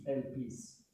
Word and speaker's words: Elpis: La parola Elpis in la pronuncia è sulla I, Elpis. La I, I. Elpis con la P.